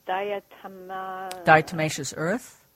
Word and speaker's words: diatomaceous earth: And I've seen, I don't even know how to pronounce it, but this diatomaceous earth.